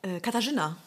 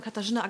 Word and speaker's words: Katarzyna: Katarzyna. – Katarzyna. – Genau, eigentlich voller Name Katarzyna Agnieszka.